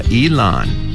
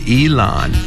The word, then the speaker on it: Elon: 이렇게 발음하시고 엘론은 엘론 머스크, 일란 머스크 많이 들어보시죠? 사사의 이름입니다. 일란. E-L-O-N. 일란. 한 번 더요.